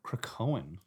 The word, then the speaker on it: Krakoan: The what language? – Krakoan. – Krakoan. – Yep. K-R-A-K-O-A-N.